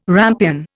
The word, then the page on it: rampion: Indian